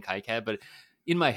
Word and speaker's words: KiCad: I say KiCad, but it's really KiCad.